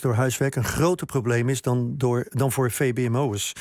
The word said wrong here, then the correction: VMBO'ers: Zeg ik dat goed? VMBO'ers, hè?